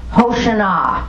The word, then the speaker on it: hoshana: Hoshana, that's how it's pronounced in Hebrew.